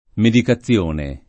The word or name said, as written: medicazione